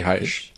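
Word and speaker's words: Hysh: Maybe the Hish one. Is that how you say it? To this day, I don't know. Is that how you say Hish? – I say Hi-ish. – Because like high it's elf.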